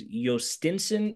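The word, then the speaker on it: Jostinson: Yes, it's spelled with a J, and it's very confusing, but it is Yostinson Garcia.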